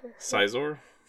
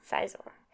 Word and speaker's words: Scizor: So, Cyclizar? How do you say that? – Cyclizar is a whole different Pokemon from... – How do you say it? – Scizor? – Scizor.